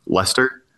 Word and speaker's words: Leicester: Leicester City, which is actually spelled Leicester. – Yeah. – Pronounced Leicester.